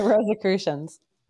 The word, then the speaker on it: Rosicrucians: The Rosicrucians.